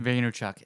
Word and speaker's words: Vaynerchuk: Vaynerchuk. – Vaynerchuk.